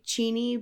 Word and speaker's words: Cheney: I say Cheney because we have Cheney Stadium, which is the home of the Tacoma Rainiers minor league baseball team.